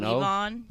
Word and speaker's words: Levon: I think it's Levon, right? With an Levon. – O? – Levon. You know who it is already? – No, but it's spelled O-N, it's Levon.